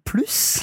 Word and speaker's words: plus: But the crucial thing, if you're using plus, is that you pronounce that S. – Oh, you've got to. Oh, yes. – Because if you don't pronounce the S, it means something different.